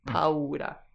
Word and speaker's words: paura: So that's spelled P-A-U-R-A, and we pronounce all of the vowels, so you get this paura.